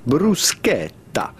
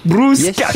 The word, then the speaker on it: bruschetta: Yes, the CH here is pronounced like it was a K, so do not say bruschetta, but rather bruschetta. – Bruschetta. – Insist on the E and the double T.